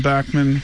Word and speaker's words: Bachman: So Bachman Sparrow, some people might call it Bachman, but John Bachman from the East Coast pronounced it such, Bachman.